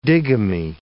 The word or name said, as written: digamy